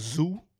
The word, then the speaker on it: ZUU: He's got a new tape called Zoo. It's coming out. Z-U-U is how it's spelled.